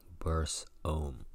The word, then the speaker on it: Barsahom: The natives pronounce this word like Barsahom, in which the U is heard like a short and softly uttered open E, as in Beth-El.